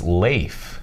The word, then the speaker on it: Leif: Leaf? – It's Leif. – No kidding. – Like a potato chip with an F at the end. – I got it. Yeah, Leif.